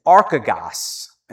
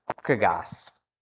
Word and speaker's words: archegos: The word is in Greek is archagos, and it's spelled A-R-C-H-E-G-O-S, archagos.